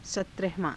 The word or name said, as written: satrahavaa~